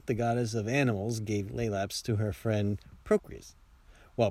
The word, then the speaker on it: Lelaps: They sound the same, but the Roman Lelaps is spelled L-E-L-A-P-S, where the Greek started with L-A-E.